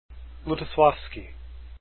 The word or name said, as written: Lutoslawski